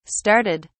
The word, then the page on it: started: British English